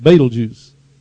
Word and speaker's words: Betelgeuse: My wife was helping me pronounce this. Let me see if I can remember how to say it. Betelgeuse. That's it. Betelgeuse. Not Betelgeuse. I said I refuse to say Betelgeuse.